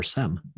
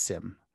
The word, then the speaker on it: SIEM: Is it SIEM or SEM? I hear SIEM. It both ways. – Yeah, it's SIEM.